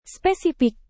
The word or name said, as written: spesifik